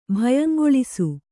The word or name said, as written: ಭಯಂಗೊಳಿಸು